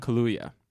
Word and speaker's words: Kaluuya: Kaluuya. That's how I would pronounce it. Kaluuya. But I mean, K-L-U-U-Y-A.